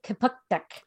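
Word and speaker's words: Kjipuktuk: So Halifax, Nova Scotia is based in, it's Kapuktuk, which is the Mi'kma'ki word.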